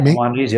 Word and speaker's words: Maimonides: is it Maimonides? Am I pronouncing that right? – Maimonides, yep.